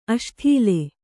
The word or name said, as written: ಅಸಡಾಳ